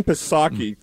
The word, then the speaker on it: Psaki: And Jen Psaki from the hard streets of Greenwich, Connecticut. – P is silent.